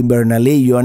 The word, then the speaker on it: Bernalillo: You would pronounce it in English, but as I've mentioned, everything in New Mexico, is if it is a word of Spanish origin, they usually pronounce it in the Spanish pronunciation, so it should be Bernalillo, and it's like a suburb north of Albuquerque.